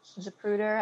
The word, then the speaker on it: Zapruder: Now, if you look at the Zapruder film, Zapruder, I believe I'm pronouncing that correctly.